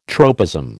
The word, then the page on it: tropism: American English